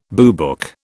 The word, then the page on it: boobook: British English